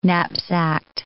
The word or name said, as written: knapsacked